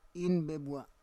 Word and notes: in bebua: Roon

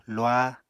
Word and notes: luah: Apatani

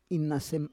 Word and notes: inasem: Roon

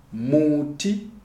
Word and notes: Muti: Shona

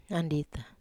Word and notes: andita: Wamesa

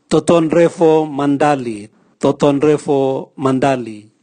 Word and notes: totonrevo mandali: Raga

